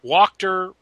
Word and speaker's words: Walkter: You don't have to read this part aloud, but for pronunciation purposes, Walkter rhymes with doctor.